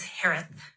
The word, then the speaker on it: Herath: Last name is pronounced like Harris with a lisp. It's Harith. H-E-R-A-T-H.